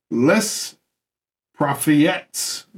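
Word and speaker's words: Les Prophéties: Catherine de Medici became one of his foremost supporters, and his very famous La Prophète. How do you say that? That's a hard word. – Where you at? – Les Prophètes, the name of his book.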